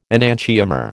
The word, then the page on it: enantiomer: American English